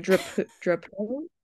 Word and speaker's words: Drapeaux: like, Drapeaux? I don't know, but it's like a softer sounding O, and I just can't replicate it.